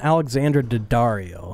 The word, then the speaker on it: Alexandra Daddario: Alessandra Daddario? – How you say her fucking name? You knew Daddario? I was talking about dickhead. – Calm down, wolf. It's fucking Alexandra Daddario. – Alexandra Daddario.